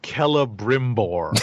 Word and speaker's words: Celebrimbor: And it's pronounced Celebrimbor.